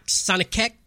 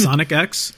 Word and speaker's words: Sonic X: Are you sure it's Sonic X and not Sonic Keck? I don't even know how you say it. Sonics. – No, it's Sonic X.